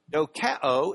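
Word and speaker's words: dokeo (δοκέω): Dokeo in the Greek. Yeah, that. Which in English letters is that with a long O, dokeo.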